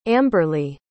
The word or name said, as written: amberley